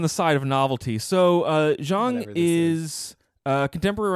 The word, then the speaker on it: Zhang: Yeah, Zhang.